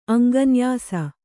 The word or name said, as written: ಅಂಗನ್ಯಾಸ